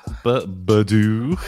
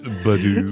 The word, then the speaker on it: Birdo: Is it Birdo? Birdoo? – Birdoo. That's the sorry, a man B-day. Of culture. – The proper pronunciation of it. Birdoo. – Fuck's sake.